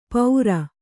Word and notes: ಪೌರ: paura

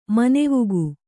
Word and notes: ಮನವುಕ್ಕು: manavukku

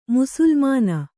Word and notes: ಮುಸುಲ್ಮಾನ: musulmāna

